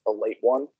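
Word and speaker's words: Ale-8-One: So it's like, it's Ale 8-1, but it's pronounced a late one.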